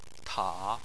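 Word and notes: ta: tone 3

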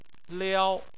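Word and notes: liao: tone 1

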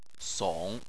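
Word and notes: song3: tone 3

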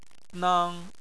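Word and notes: nang: tone 1

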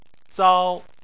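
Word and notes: zao: tone 1

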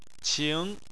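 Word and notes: qing: tone 2

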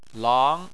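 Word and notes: lang: tone 2

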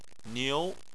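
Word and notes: niu: tone 2

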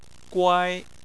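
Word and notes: guai: tone 1

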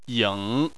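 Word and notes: ying: tone 3

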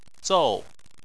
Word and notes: zou: 4

